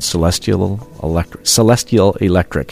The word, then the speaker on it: Celestial Electric: If you like that song, the rest of the album is really good, called Celestial Electric. Did I say that right? – Yeah. – Okay. – I think so.